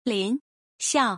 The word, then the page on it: 林肖: Lin Xiao; Slow